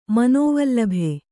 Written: ಮನೋವಲ್ಲಭ

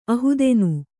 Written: ಅಹುದೆನು

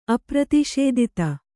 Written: ಅಪ್ರತಿಷೇದಿತ

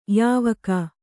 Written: ಯಾವಾಕೆ

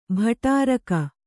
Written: ಭಟ್ಟಾರಕ

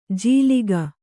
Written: ಜೀಲಿಗ

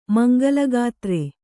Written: ಮಂಗಲಗಾತ್ರೆ

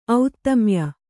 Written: ಔತ್ತಮ್ಯ

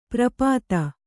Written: ಪರಿಪೀತ

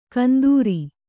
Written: ಕಂದೂರಿ

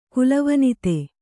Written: ಕುಲವನಿತೆ

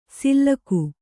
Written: ಸಿಲ್ಲಕು

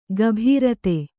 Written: ಗಭೀರತೆ

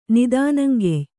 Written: ನಿದಾನಂಗೆಯ್

